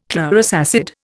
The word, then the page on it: chlorous acid: Indian